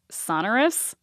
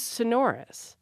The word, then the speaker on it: sonorous: That is exactly how I say it, sonorous. And so I checked, that was one of the available pronunciations on the survey, so I checked acceptable. One of the other pronunciations that they gave us to decide if it was acceptable or not was sonorous.